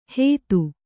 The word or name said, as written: ಹೇತು